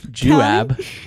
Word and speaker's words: Juab: Juab. Juab is how that would be pronounced. It's a weird spelling.